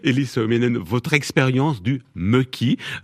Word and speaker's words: mökki: Et le troisième, mökki.